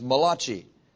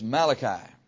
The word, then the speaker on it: Malachi: Now, if you came here this morning hungry and you would like to have a kolache, don't mispronounce the name of this book as Malachi. It's Malachi.